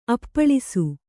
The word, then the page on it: ಅಪ್ಪಳಿಸು: appaḷisu